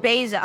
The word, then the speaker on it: Basia: And it's pronounced Beza. I'm so glad you pronounced it right. Sometimes people think it's Beza or Bathia, and I'm like, huh? So it's Beza, BASIA, the THA Bible Nerd.